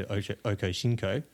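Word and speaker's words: Ochocinco: He was Chad Johnson at the time when he was with TJ in Cincinnati before he, of course, went to Okosinko. Hope I said that right. – You did. Beautiful.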